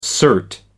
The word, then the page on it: cert: American English